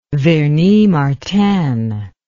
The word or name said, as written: vernis martin